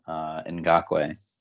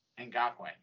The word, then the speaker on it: Ngakoue: Oh yeah, Ngakoue. – Ngakoue, thank you.